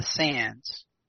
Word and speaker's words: Sandys: Okay, so the Cavendishes allied and married into Thomas Cavendish, married into this family called the Sands. It's pronounced Sands, but spelled Sandese with a Y at that time.